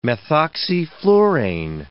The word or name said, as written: methoxyflurane